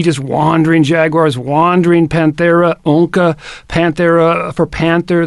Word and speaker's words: onca: The specific epithet is Greek for claw, onca. You know, that in Greek, the C, if that C is followed by the vowel A, then you got to pronounce it like a K.